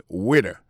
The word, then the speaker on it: waiter: Alors, moi, je prononce le waiter. Waiter, ça veut dire accent tombé sous premier syllabe là. Waiter. W-A-I-T-E-R.